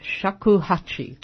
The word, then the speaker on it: shakuhachi: Your main instrument, though, is the shakuhachi.